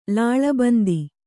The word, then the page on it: ಲಾಳಬಂದಿ: lāḷa bandi